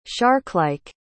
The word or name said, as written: sharklike